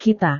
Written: kita

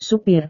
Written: supir

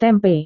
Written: tempe

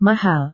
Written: mahal

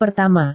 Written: pertama

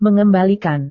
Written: mengembalikan

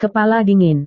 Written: kepala dingin

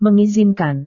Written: mengizinkan

